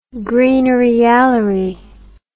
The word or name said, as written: greenery-yallery